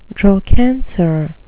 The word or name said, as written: drawcansir